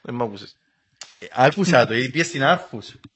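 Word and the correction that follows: Aarhus: Στην ποια? Στην Άχου, στην Δανία. Άχου, εντάξει.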